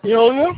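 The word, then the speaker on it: Illya: Ilya.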